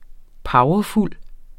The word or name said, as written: powerfuld